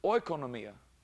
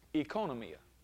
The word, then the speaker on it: oikonomia: The O-I is actually like a long E. And so instead of oikonomia, it should be translated economia.